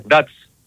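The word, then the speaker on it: DAC: Θέλω να βοηθήσετε πώς προφέρεται η ομάδα της Σλοβακίας. Ντάτς. – Ντάτς. – Ντάτς, ε. Οκ. – Ντάτς χωρίς καρπούς.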